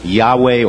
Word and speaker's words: Yahweh: Which would be pronounced Yahweh or Jehovah.